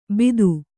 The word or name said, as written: ಬಿದು